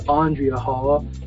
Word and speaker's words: Andrea Hall: Just it is Andrea Hall, and feel free to also, many of you, my audience out there, you tend to Google anyway, so it is it's spelled A-N-D-R-E-A, and Hall is exactly the way it sounds, H-A-L-L.